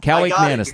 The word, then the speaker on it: Kaliakmanis: They look bad and they have a terrible Kalakaki Malaki is Callie McManus. – Kaliakmanis, thank you.